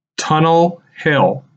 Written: Tunnel Hill

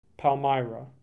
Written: Palmyra

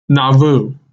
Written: Nauvoo

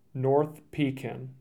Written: North Pekin

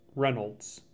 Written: Reynolds

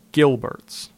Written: Gilberts

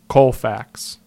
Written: Colfax

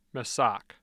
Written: Massac

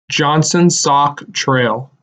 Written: Johnson Sauk Trail

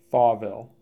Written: Thawville

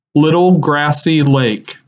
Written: Little Grassy Lake

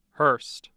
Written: Hurst

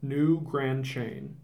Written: New Grand Chain

